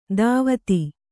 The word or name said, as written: ಧೋವತಿ